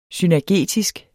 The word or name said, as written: synergetisk